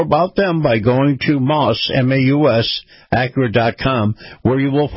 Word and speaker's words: Maus: Moss Acura. That's spelled M-A-U-S,